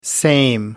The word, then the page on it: same: American English